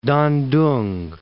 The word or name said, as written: Tan-tung